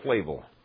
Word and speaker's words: Flavel: And John Flavel, when he caught, is it Flavel or Flavel? – Flavel. – Flavel. Thank you.